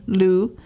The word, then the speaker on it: Lew: And she's very caring and very loving about her son, Lou, which is spelled L-E-W, which I thought, well, you know.